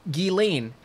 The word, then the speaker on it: Ghislaine: It's Ghislaine. How would you pronounce G-H-E-E?